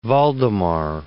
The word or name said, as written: Valdemar